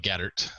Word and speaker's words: Gaeddert: Um, and then my last name is Gaeddert. It's G A E D D E R T.